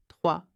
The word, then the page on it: 3: French